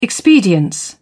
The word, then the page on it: expedience: British English